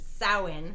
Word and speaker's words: Samhain: Now Loomis does say Samhain in the second one, and in the third one it is pronounced correctly, which is Samhain.